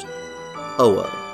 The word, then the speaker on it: Ho-Oh: In Chinese, it is named the Fang Huang, though in Japan, the name is pronounced Ho-Oh.